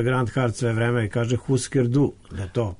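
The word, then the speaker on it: Hüsker Dü: Jeste, moja čerka je pre neki dan ukucala to i na Wikipediji ima onaj izgovor i kaže se Husker Du, čak se ovo ni Du ne čuje baš. Znači Husker Du je definitivno, da, to je danski i oni izgovaraju Husker.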